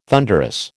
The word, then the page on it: thunderous: American English